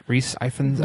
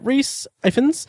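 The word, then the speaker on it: Rhys Ifans: Reese Ifans. Am I pronouncing his name right? – Reese Ifans. – Reese Ifans. – I know it's Reese. – Yeah, Reese Ifans.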